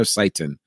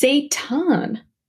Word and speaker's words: seitan: And I always know if I'm saying, is it seitan or seitan? – It's seitan. – It's seitan. Okay, yes. Mm-hmm. So pronunciation. We want to say it right.